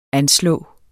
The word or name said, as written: anslå